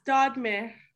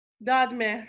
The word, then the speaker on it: Dodmer: And how do you say your last name? – It's Dodmer. – Dodmer, with the rolled R, Dodmer. – Dodmer, yeah.